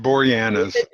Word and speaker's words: Boreanaz: I mean, yeah, Angel Bory, I can't pronounce that name, but David Boreanaz.